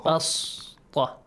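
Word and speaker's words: بسطة: Basta, right? – Yes. – Listen, how do I read it? Basta. – Yes. – Pronounce it like a seen.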